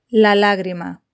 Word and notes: la lagrima: Spain Spanish

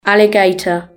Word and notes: alligator: British English

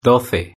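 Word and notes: doce: Spanish (Spain)

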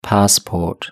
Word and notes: passport: British English

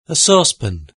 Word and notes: a saucepan: British English

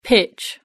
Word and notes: pitch: British English

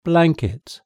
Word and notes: blanket: British English

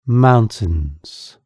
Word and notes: mountains: British English

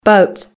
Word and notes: boat: British English

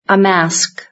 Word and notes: a mask: British English

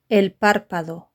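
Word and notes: el parpado: European Spanish

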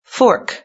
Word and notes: fork: British English

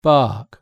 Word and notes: bark: British English

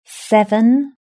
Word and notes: seven: British English

